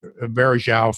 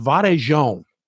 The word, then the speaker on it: Varejão: She says, it's not pronounced Varejao. She said, it's pronounced Varejão, like that, like São Paulo.